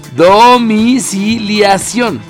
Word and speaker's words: domiciliación: Bueno, pues activa la domicialización. – ¿Lo dije bien? No. ¿Dónde está leyendo? – Aquí. Domiciliación.